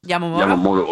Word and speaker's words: Yamamoto: Yamamoto.